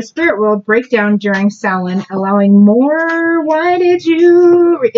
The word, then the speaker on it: Samhain: Halloween has its roots in the ancient pre-Christian Celtic festival of, okay, it looks like Samhain, S-A-M-H-A-I-N, but that's not how you pronounce it. Samhain.